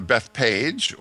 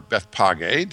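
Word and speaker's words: Bethphage: get to Bethpage or Bethpage, depending how you want to pronounce that name.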